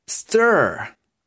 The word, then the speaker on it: stir: Se escribe Stir, pero se pronuncia como si fuera la tía Esther.